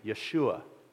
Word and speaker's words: Yeshua: And then actually in the rest of the verse, his name is defined, because you see, Jesus is the English translation of the Hebrew name Yeshua. Yeshua HaMashiach, which literally means Jesus the Messiah. That's how you'd say his name in Hebrew.